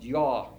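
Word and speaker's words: ja: I belong to Joggers Anonymous, J-A, pronounced yaw in Norwegian.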